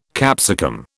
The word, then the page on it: capsicum: British English